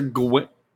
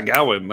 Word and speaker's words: Gawain: Sir Gawain. Yeah, Gawain, that's it.